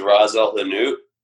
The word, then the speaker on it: Ras El Hanout: One of my favorite spice blends is Ras El Hanout, and it's spelled R-A-S space E-L space H-A-N-U-T, and that means best.